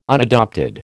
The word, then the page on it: unadopted: American English